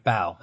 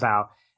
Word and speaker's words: Bao: Am I pronouncing that? It's a three letter word. How do I butcher that? – Sure. – Right? – Bao. That works. – We'll call it Bao.